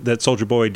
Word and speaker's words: Soulja Boy: Yes, Soulja Boy.